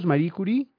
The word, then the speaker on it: Marie Curie: ¿Estoy pronunciando bien ese apellido francés, el de este par de científicos, Marie Curie? – Sí, perfecto.